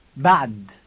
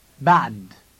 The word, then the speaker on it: بعد: It has the ah sound in the middle. Listen. – بعد. بعد. بعد. – Now repeat it. You must tense the muscles in your throat as you say it.